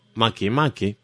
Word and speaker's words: Makemake: Uno es Quoar, el otro Makemake, que se pronuncia así, por las dudas le digo, se escribe como si fuera en inglés Makemake, pero que no es una voz inglesa.